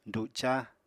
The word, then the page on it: duca: apatani